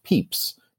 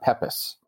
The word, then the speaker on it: Pepys: But his name is pronounced Pepys, not Pepys. So it's Knowles, not Knollys.